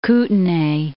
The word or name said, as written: Kootenay